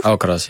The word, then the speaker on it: Alcaraz: Want je staat ook wel eens tegenover elke res. Nou, zeg ik het goed? – Alcaraz, ja.